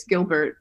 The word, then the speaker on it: Gilbert: He always pronounced it the French way. It's Gilbert, but who lived next door to the camp,